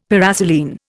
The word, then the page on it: paraselene: Indian